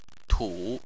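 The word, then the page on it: tu: tone 3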